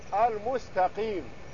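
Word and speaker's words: المستقيمُ: هذا يسأل عن كلمة في الفاتحة يقول كيف تنطق اهدنا الصراط المستقيم، يقول هل هو بكسر الميم او بضمها؟ اطلب منك ترجع الى اقرب مصحف اليك وتنظر كيف كتبت، وهي بضم الميم الصراط المستقيم وليس بكسر الميم.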